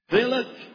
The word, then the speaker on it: Balak: Balak. You don't know to pronounce it either. Balak. Thank you.